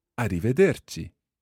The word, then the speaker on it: arrivederci: However, there is a proper word for goodbye, and it is arrivederci.